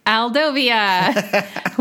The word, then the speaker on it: Aldovia: Because, you know, 2 minutes in, we get Aldovia, which rhymes with Genovia.